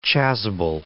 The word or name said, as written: chasuble